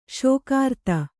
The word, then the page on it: ಷಾಗಿರ್ದು: ṣāgirdu